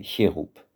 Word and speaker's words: Cherub: sauf dans des mots étrangers comme « Cherub ». Et beaucoup d'Allemands ne peuvent pas, n'ont pas le réflexe de prononcer « Cherub ». Seuls les Allemands cultivés le font.